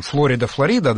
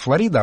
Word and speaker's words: Флорида: Ну, кстати, интересно, что про там Флорида, да, в России традиционно говорят Флорида, а вот те, кто бывает в Штатах, там ударение на другом слоге. – Мы говорим как в Штатах, Флорида.